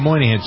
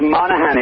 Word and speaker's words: Monaghan: Do you pronounce it Monaghan or Moynihan? Some people say Moynihan. – Yeah, it's Monaghan. It's a county in Ireland.